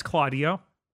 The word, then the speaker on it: Keldeo: Dan, please. It's Claudio. – Okay. Claudio is trying to fight Cobalion, who is an alien, thus why its name is said like that.